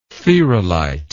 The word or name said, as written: theralite